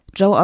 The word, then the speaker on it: Gio: Ich hoffe, das sage ich jetzt auch, äh, das, das sage ich jetzt auch richtig, weil ähm, das wird eigentlich geschrieben äh, Gio, also G-I-O.L-U-C-I-A-A. Und wir haben extra nochmal nachgefragt, wie der Name ausgesprochen wird und das Ganze wird Joe ausgesprochen, weil Joe nämlich italienische Vorfahren hat und da gerne den Bezug zu Italien hätte. Und in Italien schreibt, schreibt man das so und spricht es aber Joe aus.